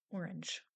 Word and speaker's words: Orange: Orange. Orange, French pronunciation.